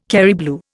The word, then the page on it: Kerry blue: Indian